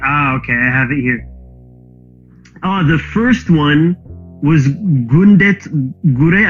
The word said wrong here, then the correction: Gura: I never know how to pronounce this, or like... – Gura.